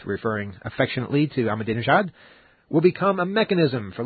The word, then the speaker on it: Ahmadinejad: And then you have different areas of the world sort of combining forces, Chavez and Iran's Mahmoud Ahmadinejad. You know, I was saying that right a couple of weeks ago. Let me get it right again. Ahmadinejad, yes, the Iranian leader.